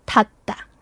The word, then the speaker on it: 닿다: But you pronounce it like 디귿, so 닿다. – Yeah, because the H or the hiut strengthens the thing.